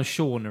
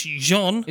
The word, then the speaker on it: Jean: Well, I wrote down Sean originally. – Sean. – Because I thought it was Sean. I thought they kept saying Sean. – It's Jean. – Is it Jean, is it? Yeah. I kept saying Sean. – J-E-A-N.